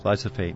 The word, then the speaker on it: glyphosate: Glyphosate. – Did I say it right? – Roundup. Glyphosate. Yeah.